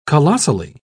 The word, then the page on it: colossally: British English